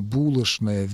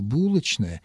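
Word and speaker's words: булочная: Старомосковский вариант требовал изменения звука «ч» в звук «ш» в сочетаниях «ч-н», правда, не во всех словах, но во многих словах. И произношение «скучно» вместо «скучно», «булочное» вместо «булочное» — это сохранившиеся остатки старомосковского варианта литературного произношения. Именно сохранившиеся и именно остатки. Сегодня произношение «булочное» и «булочное» — это варианты. Можно и так, и так.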